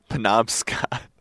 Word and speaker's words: Penobscot: Penobscot.